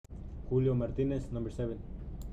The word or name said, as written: Julio Martinez